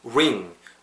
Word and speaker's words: ring: 그래서 ring R 사운드 어떻게 한다? 입술 둥글게 마시고 우, 약간 우 발음을 부은다고 생각하세요. ring, ring. 아시겠죠? 그래서 whispering, whispering. 자, 제 입, 보, 입 많은 거 보이셨죠? 보셨죠? 그 팟캐스트 들으신 분도 약간 이렇게 우라고 입을 이렇게 동글게 말고 우 해보세요. 우. 그죠? 이 상태에서 ring 뱉으세요. ring. ring. ring.